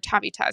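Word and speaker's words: Tavita Pritchard: And so on that staff, they had Cliff, who had been a former head coach before, with the idea that DeVita Pritchard, as the quarterback coach right under him, or Tavita is actually how you pronounce his name, could move up and use the same language for Jaden if Cliff got another head coaching job.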